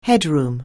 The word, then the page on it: headroom: anglais